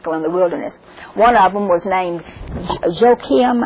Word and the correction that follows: Jakin: I never can say that right. Jakin, J-A-K-I-N.